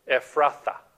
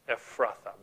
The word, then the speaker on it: Ephrathah: But Yuki, if you could go to the Micah slide there, just one pronunciation tip, okay? It says, but you, Bethlehem, and then that word, Ephrathah, Ephrathah, Bethlehem, Ephrathah.